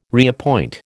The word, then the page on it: reappoint: American English